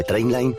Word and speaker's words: Trainline: Se escribe mejor precio garantizado. Se pronuncia Trainline. Descarga la app de Trainline y ahorra comparando miles de trenes y tarifas.